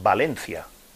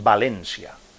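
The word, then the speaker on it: València: Y ahora no es Valencia, es Valencia, con acento en la E. Y yo, claro, como yo no domino el, valenciano, catalán, tal, pues yo no, no y a mí siempre me salía Valencia.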